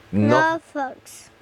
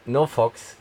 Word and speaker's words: NoFX: No Fox. – No Fox, genau.